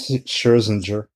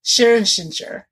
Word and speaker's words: Scherzinger: It's Jenny McCarthy, Nicole Scherzinger? I can't. – Scherzinger. – Scherzinger.